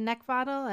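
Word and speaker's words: Neckvatal: So you can also find me on LinkedIn at Brenda Neckvottle, and that's my last name is spelled N-E-C-K, like the thing you want to choke, V as in Victor, A-T-A-L.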